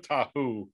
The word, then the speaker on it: Tahoe: I wrote Tahoe, like rhymes with Wahoo.